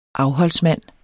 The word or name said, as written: AFHOLDSMANDEN